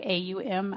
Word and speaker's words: Om: So a lot of people will just take the word Om, which technically should be pronounced like more like AUM, Aum.